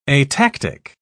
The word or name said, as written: atactic